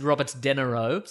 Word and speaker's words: Robert De Niro: Robert De Niro. – Yes. – World famous actor. – It's pronounced Robert De Niro.